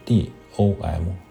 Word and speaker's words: Dom: 知识点一：德语中教堂拼写是 D O M，发音是 Dom。